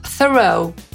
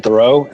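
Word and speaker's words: Theroux: Nazwisko kolejnego pana wywodzi się z języka francuskiego i wymawiamy je jako Thoreau. Justin Thoreau. – This is Justin Thoreau, and this is the timeline of my career.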